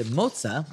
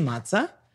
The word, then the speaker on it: mozza: It shows, because most people, when it comes to mozza, they want to say matza.